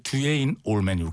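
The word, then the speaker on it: Duane Allman: 발음이 그 듀에인 올맨 이렇게 됩니다. – 이러니까 사람들이 다 아는 거야. – 아니 발음을 정확하게 하자는데 왜 듀에인. 듀에인 알겠습니다.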